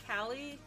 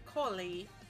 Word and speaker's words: Kali: Her name is spelled K-A-L-I. Just but like that. – I'm named after the Hindu goddess. So instead of Kali, it's Kali. But in America, or in India, it'd be Kali, right? And we just say Kali. But you can call me Kali because everybody does. Because they don't know how to read my word, read my name.